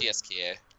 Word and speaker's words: CSKA: Is that CSKA or is CSKA? Is that how they pronounce it? – CSKA.